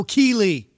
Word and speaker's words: Keeley: I don't know if I ever mentioned it, but it's a Kelly pedal. Am I saying that right? Kelly Electronics. No, Keeley. Sorry. Keeley.